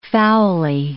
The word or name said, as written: foully